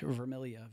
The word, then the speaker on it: Vermilya: But I'm John with an H, Vermilya, V-E-R-M-I-L-Y-A.